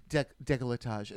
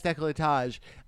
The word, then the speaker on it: décolletage: Even during the judging, I think RuPaul was like, blah, blah, blah, blah, blah, how do you say that word? And Michelle's like, décolletage. And she's like, oh, yes, décolletage.